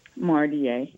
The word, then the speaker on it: Mardia: Doctor, did I say your name correctly? – Mardia. – Mardia Stone. Okay, very good.